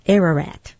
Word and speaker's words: Ararat: Also on Nisan 17, Noah's Ark landed on Mount Ararat. New life as well. – Ararat.